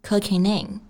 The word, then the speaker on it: cooking in: 我们在读这些话的时候呢，要注意 cooking 和 in 这两个单词可以连读，连读成 cooking in， cooking in。